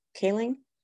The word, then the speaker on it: Kaling: Oh my god, how do you say her last name? Kalik, Kaling. Uh huh.